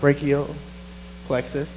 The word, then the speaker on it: brachial plexus: She shares her testimony as a means of spreading awareness about brachial plexus, pronounce that right? – Yes.